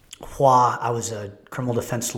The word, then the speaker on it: Hoa: H-O-A is his first name, pronounced Hua.